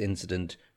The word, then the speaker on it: incident: Incident, and get that T sound at the end, incident.